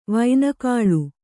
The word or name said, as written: ವೈನಕಾಳು